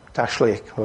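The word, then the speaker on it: Tashlich: Okay, there are just two with the Tazlik ceremony, what's called the Tazlik. Not sure if that's how you pronounce it. Somebody might correct me afterwards. What is it? – Tashlik.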